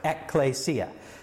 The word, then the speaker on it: ekklesia: That's how you pronounce it, ekklesia.